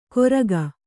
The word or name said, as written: ಗೊರಗ